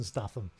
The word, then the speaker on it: Statham: If he's got Jason Statham in, I'm going to enjoy it simply because he's playing Jason Statham. – No, it's not Jason Statham. It's Jason fucking Statham. That's how you say his name.